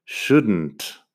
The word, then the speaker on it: shouldn't: Así que eso es bastante sencillo, pero tienes que acordarte de no pronunciar la L nunca en estas palabras. En negación es Igual no tengo ejemplos en la web, pero las formas negativas, wouldn't, couldn't, shouldn't. La L no suena, la N y la T al final sí.